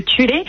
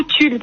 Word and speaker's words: Thule: Et donc juste pour ceux qui se diraient, mais qu'est-ce que c'est que cette marque que Tulé ? Alors en France, on prononce beaucoup Tulle parce que ça s'écrit T-H-U-L-E.